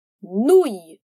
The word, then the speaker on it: grenouille: Cuidado, pois a letra E no fim não é pronunciada, tá bem? Então, vamos repetir agora tudo junto. Grenouille. Cool! Agora, dentro de um contexto. C'est une grenouille ou un crapaud?